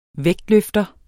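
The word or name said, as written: vægtløfter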